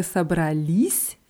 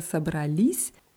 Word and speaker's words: собрались: Куда вы собрались? Ja, die Betonung ist auf dem i. Куда вы собрались?